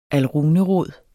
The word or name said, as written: alrunerod